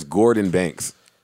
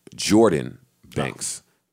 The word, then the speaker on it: Guordan: all right, so I'm going to play a record by, I always pronounce this guy's name as Gordon Banks, and every time I do, he gets angry about it and tweets me, and he loves the fact that I support him so, but he hates how I mispronounce his name. – What's his name? – He says it's pronounced Jordan Banks, but it's G-U-O-R-D-A-N, Banks.